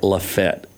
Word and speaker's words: Lafayette: Number two, if you want to win over some locals next time you're down, Rob, instead of Lafayette, which is what a lot of people, just say it like Lafette, L-A-F-E-T-T. They'll be like, oh, he knows. He's from here. – He knows. – He knows. That's what the locals refer to it as.